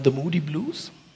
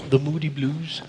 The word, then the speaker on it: The Moody Blues: Dieses Lied ist von einer Gruppe namens The Moody Blues. Habe ich doch richtig gesagt? – Ja, hast du. – The Moody Blues.